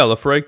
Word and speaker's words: Laphroaig: Did you find out if you pronounce the G? – You do pronounce the G. I like it. I like a hard G, you know? Um, so yeah, Laphroaig 10.